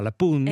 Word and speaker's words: La Punt: Et moi, j'ai appris comme parole, je ne savais pas qu'on dit, on dit pas le pont, on dit la, donc c'est féminin, pun, alors qu'il y a un T à la fin, mais on ne le prononce pas, la pun.